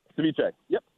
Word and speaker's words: ceviche: Ceviche. Yep.